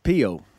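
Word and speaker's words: Peo: Pio.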